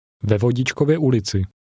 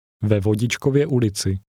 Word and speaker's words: Ve Vodičkově ulici: Ve Vodičkově ulici.